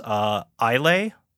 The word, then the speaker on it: Islay: Because it's everywhere in Scotland, or at least on the island of, oh, I'm gonna butcher the name of this, Islay.